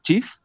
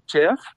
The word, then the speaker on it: Cheaf: Y como bien lo dijo, se llama Shif y se escribe C-H-E-A-F, Shif.